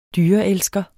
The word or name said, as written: dyreelsker